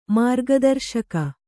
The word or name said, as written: ಮಾರ್ಗದರ್ಶಕಿ